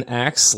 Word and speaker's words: Axe: My real name is Ben Axe. Last name is, spelled A-X-E. It's like, oh, that'd be great to throw in. It kind of rhymes with activity, if you throw it in there.